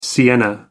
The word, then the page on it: sienna: American English